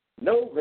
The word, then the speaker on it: Novae: the Lifestyle Enhancement Company, N-O-V-A-E, Novo, Nove, is that how you pronounce it, Nove? – Yes, that's correct. – Nove, a lifestyle enhancement company.